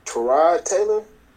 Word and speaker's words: Tyrod Taylor: Is it Tyrod Taylor? – Oh God, yes.